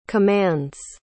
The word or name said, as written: commands